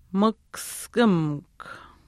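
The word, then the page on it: mihkuskimikw: Chisasibi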